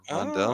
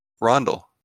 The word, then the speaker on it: Rondel: I know how it's spelled, and I might make an ass of myself by mispronouncing it, but it's R-O-N-D-E-L. It's Rondel, or Rondel? – Oh, I think it's Rondel. – I think it's Rondel as well. – Someone can correct me later, but it's Rondel.